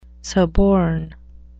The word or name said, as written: suborn